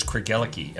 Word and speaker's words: Craigellachie: And that is Craigellachie. Am I saying that correctly? – You are.